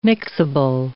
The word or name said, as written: mixable